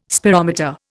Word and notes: spirometer: Indian

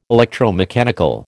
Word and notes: electromechanical: American English

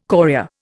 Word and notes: chorea: Indian

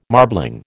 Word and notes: marbling: American English

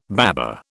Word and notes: baba: British English

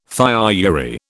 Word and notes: thiourea: British English

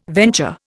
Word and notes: venture: Indian

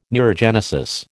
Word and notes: neurogenesis: American English

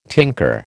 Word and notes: tinker: American English

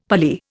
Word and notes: Pali: Indian

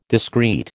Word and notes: discreet: American English

